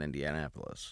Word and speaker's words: Indianapolis: and I used to remember when he was the host at Crackers in Indianapolis.